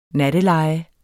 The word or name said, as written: natteleje